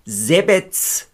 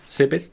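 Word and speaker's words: Zebec: Der Trainer vom HSV hieß Branko Sebets. – Ah, wusste nie, wie man den ausspricht. Ich habe den nie gehört, ausgesprochen, glaube ich, immer nur gelesen. – Es heißt aber Alexander Ristić, aber Sebets. Branko Sebets.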